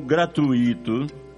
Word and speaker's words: gratuito: Gratuito ou gratuito? O certo é gratuito, com U tônico. A sílaba tônica recai no U e não no I, e não leva acento gráfico.